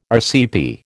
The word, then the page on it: RCP: American English